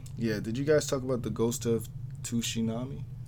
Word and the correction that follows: Tsushima: First of all, it's called the Ghost of Tsushima. – Oh, there you go. – Okay. Oh, man. You and King of Hearts are so bad at pronouncing these Japanese names.